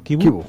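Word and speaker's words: Chivu: En Tuttosport, Kibu, el técnico del Inter, se pronuncia así, ¿no? Kibu. – Kibu, sí.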